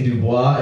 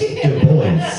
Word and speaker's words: Du Bois: and so I made that joke because I wanted to say Du Bois in a funny way, and then someone, a white man, came after me after a set and was like, "Did you know that it's actually pronounced Du Bois?"